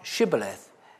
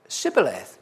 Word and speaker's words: Shibboleth: If he said, 'No,' then they said to him, 'Say now Shibboleth.' And he said, 'Sibboleth,' for he could not frame to pronounce it right.